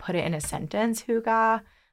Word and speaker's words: hygge: So they have this term, and I don't know if I'm pronouncing it correct, but I think it's pronounced hygge.